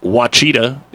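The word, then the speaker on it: Ouachita: Peter Yazvak, who there isn't anybody better than this sports information director, says Wachita is how it's pronounced, and it is a full-fledged Division II school.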